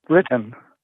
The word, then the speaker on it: written: Retent, R-E-T-I-N-T plus W. And this is another one of those words that starts with a silent W. – Written. – That's it. And here's your last one. No shame. N-O-S-H-A-M-E plus D as in dog.